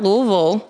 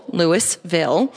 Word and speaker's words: Louisville: Not Louisville, Louisville.